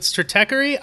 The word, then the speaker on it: Stratechery: Stratechery. I, I never really know how to pronounce that word. I've never had to say it out loud before. Um, he's the Prince of U Street. You nailed it.